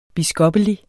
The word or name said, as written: biskoppelig